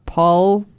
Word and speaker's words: Paul Gauguin: And I don't know how they're pronounced, but Paul Gauguin? – Pretty good.